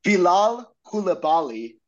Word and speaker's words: Bilal Koulibaly: Liam, double check me if I'm pronouncing this wrong, but Bilal Koulibaly.